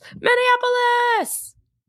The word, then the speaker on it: Minneapolis: She goes, Minneapolis! – Oh, Minneapolis? Oh, that makes sense. Because when I see it, I want to give it way more syllables.